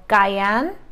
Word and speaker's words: Gyan: In Sanskrit, I believe this is called Gayan, but me and my Sanskrit are not always on the same page, but it's spelled G-Y-A-N, and this is the most popular mudra you'll see.